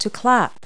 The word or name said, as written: clap